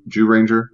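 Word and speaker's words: Zyuranger: I think they have 8 or 10 seasons out now going back to Jew Ranger. Yes, it's spelled ZYU, but it's pronounced Jew, JOO.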